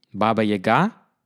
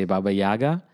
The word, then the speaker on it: Baba Yaga: The three miniatures, Baba Yaga, and that's the right way to pronounce it, but you can also say Baba Yaga, Kikimora, and The Enchanted Lake, are full of mythic fairy tale mystery.